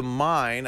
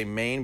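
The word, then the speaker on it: Main: Danube, the Mine. I always say Maine, but I've been told it's pronounced the Mine and the Rhine.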